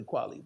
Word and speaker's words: Kweli: Today, underrated bars of fame is Talib Kweli, or Kweli, however people want to pronounce it. It's really Kweli, but people say Kweli still.